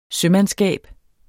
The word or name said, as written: sømandskab